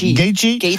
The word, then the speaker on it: Gaethje: Principal entre Ilia Topuria, la mégastar, et Justin Gaethje. Comment on prononce ? – Gaethje.